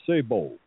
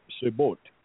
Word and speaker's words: sabot: That blue shoe is called a sabot. The T is silent. Yep, sabot. But the T is silent. It's a French word.